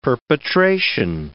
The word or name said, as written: perpetration